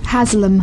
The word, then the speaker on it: Haslam: Uh, how do you say exactly? Haslam? – Haslam. – Haslam. – Haslam. It's a northern name.